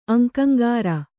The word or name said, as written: ಅಂಕಂಗಾರ